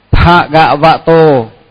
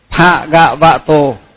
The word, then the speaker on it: Bhagavato: Bhagavato. Bhagavato. Chữ chữ pha là nhớ là dòng môi, tức là phải đọc dòng môi. Pha gạ vạ tô,